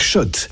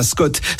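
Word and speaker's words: Schott: Jouer et gagner sur RMC avec Shot, la marque iconique new-yorkaise depuis 1913. Oui, Shot. Et pas Scott, ça fait des années qu'on prononce mal la marque.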